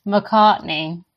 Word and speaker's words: Macartney: McCartney. Como, el Paul McCartney de, de los Beatles.